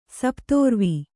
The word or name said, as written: ಸಪ್ತೋರ್ವಿ